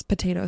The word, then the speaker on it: potato: Noticed how I pronounced potato, potato salad. So when there's two T's like this and they're separated by a vowel, it's generally correct to pronounce the first T as a T, ta ta ta, and the second T as a D, da da da.